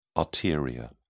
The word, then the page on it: arteria: British English